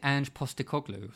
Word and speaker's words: Ange Postecoglou: Is it Ange Postecoglou or something? – Oh, you're good. I wouldn't be able to say it. – Yeah. – Ange Postecoglou. – Ange Postecoglou.